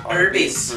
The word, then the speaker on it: Arby's: Kelsey told me today that Arby's actually is pronounced like the initials Arby's for roast beef.